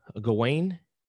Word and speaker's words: Gawain: Another thing when I was going through the book, there is one of these moments that is in the tale of Sir Gawain. Am I pronouncing that right? – Yeah.